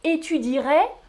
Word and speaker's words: étudierai: Étudierai, on écrit le E mais on ne le prononce pas du tout.